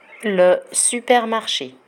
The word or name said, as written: le supermarche